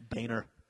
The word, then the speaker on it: Boehner: It's Boehner.